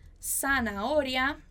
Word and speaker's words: zanahoria: Solo una ensalada de lechuga, zanahoria e tomate. Só uma saladinha, então, que ela comeu, né? Só uma salada de alface, cenoura e tomate. Então, muito cuidado com essa pronúncia. Zanahoria. Zanahoria.